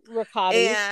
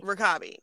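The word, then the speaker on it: Rekabi: Her name was Elnaz Rebecca B. I don't know how to pronounce that. But hello, girl. – Rakabi. Elnaz Rakabi. – Rakabi. There we go.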